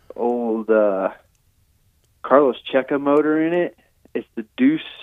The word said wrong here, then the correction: Deus: Deus. You know, Deus Ex Machina is the proper way to say that.